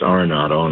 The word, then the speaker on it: Arenado: and I wasn't even pronouncing his name right then, and it's Arenado, and I'm sorry.